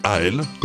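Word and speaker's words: Aël: Il s'appelle A.L., comme ça se prononce, A-E-L.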